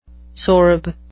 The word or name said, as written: sorb